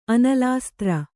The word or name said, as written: ಅನಲಾಸ್ತ್ರ